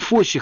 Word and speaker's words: Фосси: Во-вторых, постановщик Боб Фосси. В России его фамилию произносили ошибочно Фосс, полагая, что последнее «и» не читается.